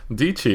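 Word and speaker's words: Dietschy: So it means that if you're on Anchor and you're like Gary Vee or Sarah DiCicci? – DiCci, it rhymes with peachy.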